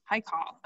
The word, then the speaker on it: Haikal: What about, so we were talking about the first time Todd Pletcher starter, the 4, and I don't want to say it wrong. Is it Haikal? – Haikal.